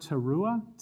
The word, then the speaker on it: Teruah: It's Y-O-M, Yom, second word, Teruah, T-E-R-U-A-H.